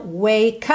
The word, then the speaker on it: wake: Então, wake up, juntando ali, já que o E do wake não é pronunciado.